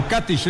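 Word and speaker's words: Katić: O Katic, ellos le ponen el acento en la A.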